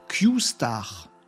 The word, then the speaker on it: Q-Star: Q-Star. Q étoile. Q astérix. Ça se prononce Q star, d'après ce que j'ai compris.